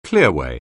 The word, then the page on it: clearway: anglais